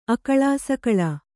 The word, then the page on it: ಅಕಳಾಸಕಳಾ: akaḷāsakaḷā